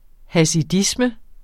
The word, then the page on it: hasidisme: hasiˈdismə